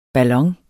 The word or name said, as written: ballon